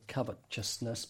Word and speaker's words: covetousness: We thought Americans mispronounced the word covetousness because we couldn't see the ch in there. And it's taken me a long time to be able to say covetousness without saying it, as we say it overseas, covetousness.